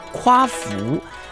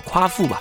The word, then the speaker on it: 夸父: 中国古代有许多传说，其中有一个强哥很喜欢的故事，叫做夸父逐日啊。这个夸呢，就是那个夸张的夸字，去掉言字旁。这个夸父夸父啊，以前也常念作夸父。我们今天用一个比较现在常用的方法，就叫做夸父吧。